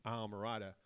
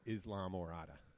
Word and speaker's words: Islamorada: I tell them Islamorada. – Well, that is how you say it in Spanish.